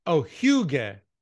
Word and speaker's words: hygge: And it's pronounced, thank you for writing down the pronunciation. Hygge. Hygge.